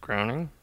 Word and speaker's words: Groening: Groening? – Yeah.